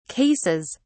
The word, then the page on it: cases: British English